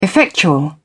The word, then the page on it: effectual: British English